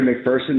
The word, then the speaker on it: McPherson: My name is Kevin McPherson. That last name is spelled M-C-P-H-E-R-S-O-N.